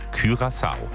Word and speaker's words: Curaçao: Deutschland dann Sonntag gegen Curaçao. Schon gesagt heute, ja, das spricht sich wirklich so. Ne? Jahrelang haben wir Blue Curaçao gesagt, hier der Cocktail. Also ich zumindest. Nein, laut Duden, Curaçao.